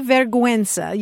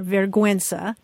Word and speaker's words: vergüenza: You can say vergüenza. You don't have to roll your R. Vergüenza.